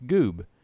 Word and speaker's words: Goob: I go, oh, it's Goob. And he's like, can you spell that? I go, oh, it's G-O-O-B.